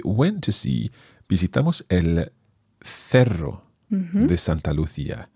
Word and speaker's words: cerro: And it's C-E-R-R-O. So in Spain Spanish, we're pronouncing that as cerro. But if you're more used to Latin American Spanish, then of course you would hear el Cerro de Santa Lucía.